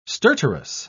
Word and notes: stertorous: British English

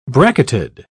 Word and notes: bracketed: British English

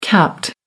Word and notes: capped: American English

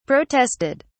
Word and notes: protested: British English

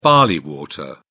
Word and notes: barley water: British English

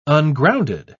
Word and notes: ungrounded: British English

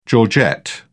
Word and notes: georgette: British English

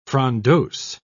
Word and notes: frondose: British English

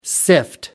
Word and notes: sift: American English

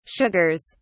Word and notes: sugars: British English

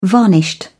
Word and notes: varnished: British English

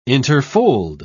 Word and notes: interfold: British English

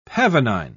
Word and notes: pavonine: British English

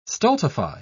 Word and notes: stultify: British English

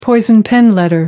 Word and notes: poison pen letter: American English

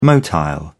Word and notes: motile: British English

